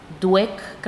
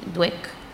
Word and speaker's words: Dweck: Y fíjate, leía yo, más adelante se menciona a la doctora Carol Dweck, creo que se pronuncia así, Dweck.